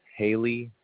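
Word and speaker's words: Haleigh: The first and last name is going to be Haley, that's spelled Hotel, Alpha, Lima, Echo, Indigo, Golf, Hotel.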